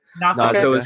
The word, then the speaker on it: Nausicaä: Now, years before, Miyazaki had to make cuts to one of his first films, Nausicaä of the Valley of the Wind, if I'm saying that right. – Nausicaä.